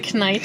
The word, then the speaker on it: Knight: The K is silent, but I like to put it there anyway. – The Knight.